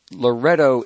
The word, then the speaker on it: Loreto: Well, you know, you did touch on Loreto, Italy. Is that how you pronounce it? – Yes. – L-O-R-E-T-O. – Yes.